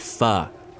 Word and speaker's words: pho: Pho. It's pronounced pho. Vietnamese noodle dish.